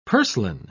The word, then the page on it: purslane: British English